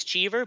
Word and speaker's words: Cheev: My last name is Cheever, but I've gone by Cheev for just since I was in elementary school.